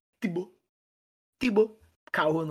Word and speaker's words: Thibaut Courtois: Thibaut Courtois. Para aqueles que vocês, hum? para aqueles de vocês que não falam francês, a pronúncia é Tibo Tibo Caô, não sei falar francês.